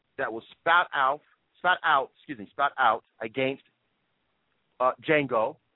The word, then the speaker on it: Django: If you saw a movie where the character clearly says in the movie and in the trailer, folks, it's Django, the D is silent.